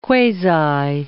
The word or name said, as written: quasi